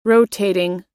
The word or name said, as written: rotating